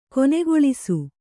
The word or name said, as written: ಕೊನೆಗೊಳಿಸು